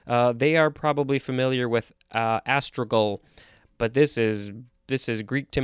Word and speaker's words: astragal: I think it's astragal. A-S-T-R-A-G-A-L. This is a noun from 1563. If I didn't look at the pronunciation guide, I'd probably want to say astragal because it's with a G-A-L at the end.